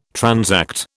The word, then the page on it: transact: British English